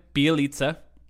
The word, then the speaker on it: Bjelica: They signed Nemanja Bjelica.